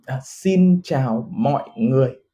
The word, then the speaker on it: Xin chào mọi người: Xin chào, how do you say it? – Xin chào mọi người. Okay. – Xin chào mọi người. Yes, that's good.